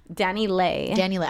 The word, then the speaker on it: DaniLeigh: In the most friendly way, pronounces her name. Danny Lay. – I was going to say Danny Lee.